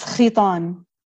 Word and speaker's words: Khitan: In in Arabic it's khitan.